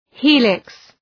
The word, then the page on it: helix: ‘hi:lıks